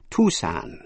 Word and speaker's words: Tucson: Chúng ta đã học tên thành phố ở Arizona, Tucson, đọc là Tucson.